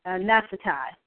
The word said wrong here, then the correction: Naphtali: But Adar is a month, and the tribe is Naphtali. It's Naphtali.